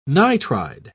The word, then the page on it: nitride: British English